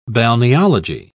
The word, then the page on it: balneology: British English